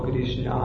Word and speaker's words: Krishna: But the actual pronunciation of Krishna is Krishna, or na. The last na is You start to say ra, and then you roll the tongue against the root, ra or na.